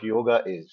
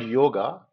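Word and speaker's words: yog (योग): The original Sanskrit word yoga, or it's actually in India, the Sanskrit word is not pronounced as yoga, it's pronounced as yog. It's just yog. There's no A.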